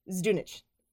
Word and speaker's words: Zdunich: There's pronunciation notes in Wikipedia. Zdunit. – Zdunik. – Zdunich. – Zdunich. – Terrence Zdunich.